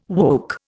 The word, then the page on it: woke: Indian